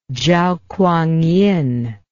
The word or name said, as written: zhao kuangyin